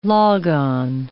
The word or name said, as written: log-on